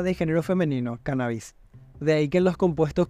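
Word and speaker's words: cánnabis: En cuanto a la acentuación, el término cannabis debe llevar tilde en la primera A, según Díaz Rojo, pues es una palabra esdrújula, ya que procede del latín cannabis, y en dicha lengua se pronuncia como drújulas todas las palabras que poseen la segunda sílaba breve, según Díaz Rojo.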